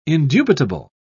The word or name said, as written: indubitable